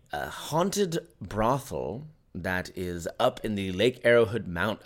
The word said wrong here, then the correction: Arrowhead: Did I say that wrong? – Arrowhood. Oh, wow. Arrowhead would be cool. Because that's just arrow.